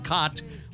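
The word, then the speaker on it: Kaat: Rolled up 283 wins, won more gold gloves than anybody in the history of the game, and was nicknamed Kitty, although his last name is not pronounced Cat, as you know, but Cott.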